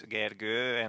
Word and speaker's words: Gergő: My name also has like two lines on top of the O. It's Gergő, and most of the people I talk to can't really pronounce it.